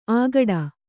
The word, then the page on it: ಆಗಡ: āgaḍa; Kannada